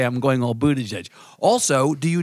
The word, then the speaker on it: Buttigieg: I'm going all Buttigieg on you.